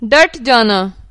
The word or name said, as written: डटना